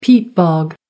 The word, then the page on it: peat bog: American English